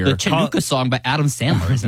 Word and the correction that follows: Hanukkah: I don't know how to say it. How do you say it? – The Hanukkah song? – Oh, is that what it is?